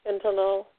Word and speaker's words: fentanyl: So, and opioids can be made from poppy plants like morphine, or it's made in the laboratory like, how you pronounce it, fentanyl? Did I say it right? Fentanyl. F-E-N-T-A-N-Y-L.